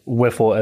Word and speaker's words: WIFL: Yeah, throughout our conversation, we discussed things like what is WIFL, as the acronym is pronounced, and what their mission is.